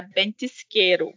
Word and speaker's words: Ventisqueiro: Tem uma vinícola chilena que eu gosto muito, que é a Bentisqueiro, que é com V, gente, mas a pronúncia é B.